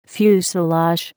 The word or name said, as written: fuselage